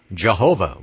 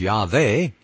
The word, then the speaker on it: Yahweh: Christians erroneously or incorrectly pronounce the word Jehovah with a J. Modern scholarship, not the original, but modern scholarship suggests that the original pronunciation was Yahweh, but in any case, there isn't, and there wasn't any W.